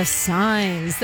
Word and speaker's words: Sines: Some more local music on 91X from The Signs. That's I Heard a Rumor. I love it so much. You can find it on their EP called Follow the Signs. And yeah, it's spelled S-I-N-E-S.